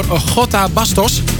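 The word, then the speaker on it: J. Bastos: En die was voor Gota Bastos. En je schrijft J.Bastos. En dan spreek je de J uit in het Spaans als Gota.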